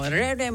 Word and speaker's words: Rere: At tama bang bigkas ko dito, Rere? – Tama, kapatid niya si Ruru. Rere Madrid.